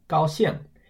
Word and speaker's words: 高兴: And then 高兴 means happy. That's pronounced first tone, fourth tone, 高兴.